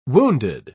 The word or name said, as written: wounded